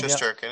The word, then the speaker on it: Shesterkin: But they're, you lose to, I don't even want to, Shesterkin? Is that how you say his name? – Shesterkin, yeah.